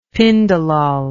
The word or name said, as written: pindolol